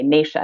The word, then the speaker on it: Nasha: It's so common, but my name is with a long A, Nasha. I think Echinacea.